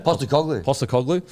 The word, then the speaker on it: Postecoglou: Postecoglou.